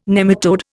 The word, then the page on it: nematode: Indian